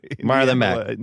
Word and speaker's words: Marlon Mack: Did I say his name right? Is that his name? Is that actually the name? – Marlon Mack. You got it. Yeah, you nailed it.